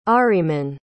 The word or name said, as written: ahriman